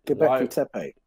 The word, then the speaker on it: Göbekli Tepe: And the next question then is, can share any thoughts on, I don't know how to pronounce this, Göbekli Tepe? Who made it? – Oh, yeah, Göbekli Tepe.